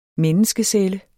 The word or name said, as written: menneskecelle